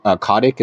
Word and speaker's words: Kotick: but Bobby Kotick, is that how you pronounce it? – Yep.